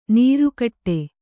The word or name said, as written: ನೀರ್ಕುಡಿ